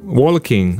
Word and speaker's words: walking: This is a pronunciation issue. Walking. Don't say that little. You don't need to. Walking. Similar ones. Talking. No L pronounced in it. It's written, yes. You write the L. The consonant is there, but not pronounced.